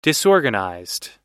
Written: disorganized

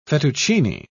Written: fettuccine

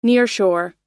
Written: nearshore

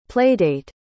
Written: playdate

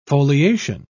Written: foliation

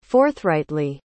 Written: forthrightly

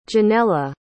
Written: Janella

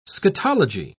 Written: scatology

